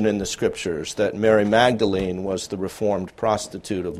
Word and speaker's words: Magdalene: In fact, even today, the term Magdalene, or as the Brits pronounce it, maudlin,